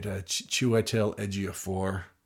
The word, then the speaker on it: Chiwetel Ejiofor: I even looked up how to say it, Chiwetel Ejiofor.